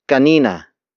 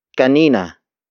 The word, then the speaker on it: kanina: The next word is kanina. Kanina. Kanina. Kanina is spelled as K-A-N-I-N-A.